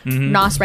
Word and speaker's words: Nas: I remember reading an article on this song and Lil Nas, Nas, right? That's how you pronounce it.